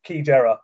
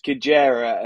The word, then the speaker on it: Xigera: She's number 13 and Key Jera, but it's spelled completely differently. – If you are looking at this race, it's the 8:20 at Keeneland on Friday. It's Kijera, and the spelling is Xigera. Sounds like nothing like how it's spelled.